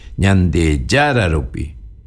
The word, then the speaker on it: ñande yararupi: En cambio, el que le estoy diciendo ahora que es una posposición, tiene que ir acento antes, ñande yararupi, chesurupi, cherurupi, ¿ve? No acento rupi.